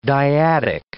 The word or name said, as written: dyadic